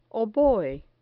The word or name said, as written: oboe